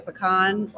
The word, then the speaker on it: Pecan: The Pecan, or maybe the Pecan Festival. – You said it right the first time.